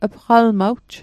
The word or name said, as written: pqalmawj